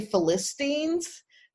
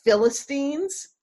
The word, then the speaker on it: Philistines: I may say Philistines, but it's pronounced Philistines. And that's because of having Bible stories read to me and in which they were all called Philistines.